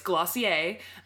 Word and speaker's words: Glossier: And it looks like it says Glossier, but she prefers that you pronounce it Glossier. – Ugh. – Yeah, so it's Glossier.